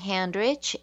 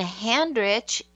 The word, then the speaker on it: Handrich: My full name is Debbie Handrich, and Debbie is spelled D-E-B-B-Y. And Handrich is just like it sounds. It's H-A-N-D-R-I-C-H.